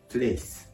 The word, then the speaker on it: place: place これは基本的な単語ですね。あえてで言うと、発音、 L の発音、 place、 place。この L の発音を気をつけましょう。